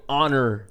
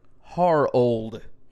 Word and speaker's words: Harold: Vince Young called him Honor Landry. – Honor Landry. – There's no If you just enunciate it. If you just, like, phonetically sound it out, you'll say it right. – Like, even if it was Harold. Yeah. Like, Harold, that'd be fine. – Yeah. – But honor is not fine. Vince, we can't do that.